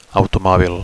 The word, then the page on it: automobil: Catalan